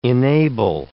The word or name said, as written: enable